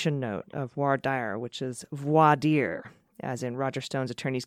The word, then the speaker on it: voir dire: Voir dire, got it.